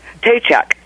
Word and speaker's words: Tejek: Tejek. – Oh. – We were close. – It's almost, it almost sounds like paycheck.